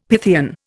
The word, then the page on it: Pythian: Indian